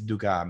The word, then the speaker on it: Dukas: the Sorcerer's Apprentice by Paul Dukas. Is it Dukas? No, it's Dukas because he's French.